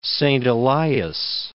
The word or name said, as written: Wrangell-Saint Elias National Park